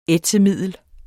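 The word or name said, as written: ÆTSEMIDDEL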